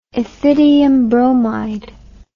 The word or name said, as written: ethidium bromide